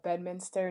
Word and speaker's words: Bedminster: I grew up in Bedminster, New Jersey.